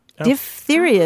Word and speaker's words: diphtheria: Diphtheria is the way you pronounce it, because part of the characteristics of that disease is that you get sort of a leathery membrane in your mm-hmm.